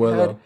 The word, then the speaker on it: Pozuelo: And then, forgive me if I'm saying this name wrong, but Pozuelo, one of their designated players. – Oh, Pozuelo.